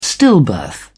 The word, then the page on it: stillbirth: British English